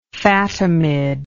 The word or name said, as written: Fatimid